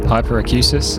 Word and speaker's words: hyperacusis: I think I've pronounced that correctly. Let me check. Yes, hyperacusis, which is a severe sensitivity to kind of everyday sounds.